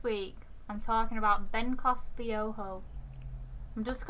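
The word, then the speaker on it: Biohó: Spelled B-I-O-H-O. – Biojo. – It depends where it's from. If it's like in the Spanish language, then that's right, Biojo.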